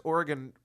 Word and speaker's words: Oregon: I pronounce Oregon incorrectly every time I think. – I've always had trouble with that. – Somebody took me to school today. They're like, it's Oregon.